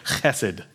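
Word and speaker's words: chesed (חֶסֶד): I love making y'all say Greek and Hebrew things, and this is really fun because you have to say it from the back of your throat. Say chesed. Yeah, if the guy in front of you is not wiping the back of his head, then you didn't say it right.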